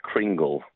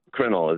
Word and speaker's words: kringle: You may know of it as kringle, but it's crinkle is the way it's properly pronounced.